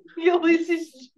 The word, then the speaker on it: Ulysses: Ulysses? – Ulysses S.